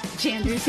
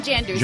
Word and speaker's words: Jondro: Mr. Coy Jandrew's here. How do you say your last name? – Jandrew. – Jandrew or Jandrew? Say it properly. – Jandrew. – Jandrew. – It's like J-O-N-D-R-O.